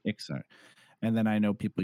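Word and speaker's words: Iksar: Everyone made an Ixkar. Maybe we can talk about that. How do you pronounce the lizard race? – Ixar.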